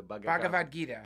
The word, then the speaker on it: Bhagavad Gita: He gave me the book I can never pronounce correctly, the Bhagavad Gita. – Bhagavad Gita.